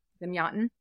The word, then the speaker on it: Zamyatin: Zamyatin.